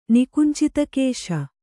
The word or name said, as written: ನಿಕುಂಚಿತಕೇಶ